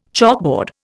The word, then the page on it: chalkboard: Indian